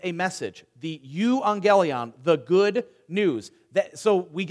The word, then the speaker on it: euangelion: Euangelion. Now, I have a friend here who speaks modern Greek. Loyal, euangelion in modern Greek is pronounced how? Evangelion. Euangelion became evangelion.